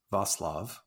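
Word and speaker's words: Václav: Well, what I can tell you is that here his name is pronounced Vaclav with a soft C.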